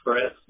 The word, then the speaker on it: Chris: So it's Chris, C-H-R-I-S. Last name is spelled W-O-B-L.